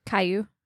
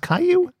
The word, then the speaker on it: Caillou: A couple episodes of Kalaloo, which I think is a kid's show. – It's Caillou. – He wrote Kalaloo. – It's a bad show. – It's spelled C-A-L-I-L-O-U, and it's pronounced Caillou? – Yeah.